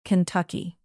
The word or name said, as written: kentucky